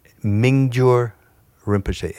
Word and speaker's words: Mingyur Rinpoche: Mingyur Rinpoche. M-I-N-G-Y-U-R Rinpoche. – Rinpoche.